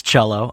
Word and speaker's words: cello: Is it pronounced Jello? Cello? Cello? – The word's cello. Uh-huh. – Oh, okay. – Like the string instrument.